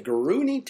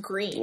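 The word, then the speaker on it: Gruene: This little historic city of Gruny, Texas. – What? – Gruny, Texas. – I think it's pronounced green. – Well, it's spelled Gruny. – That's because it's German.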